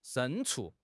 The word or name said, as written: 牲畜